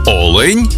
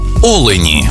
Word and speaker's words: олень: і в однині, і в множині наголос падає на перший склад. Олень та олені.